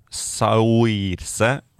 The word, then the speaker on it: Saoirse: Heter Saurs Ronan. Saoirse Ronan.